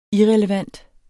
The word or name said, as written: irrelevant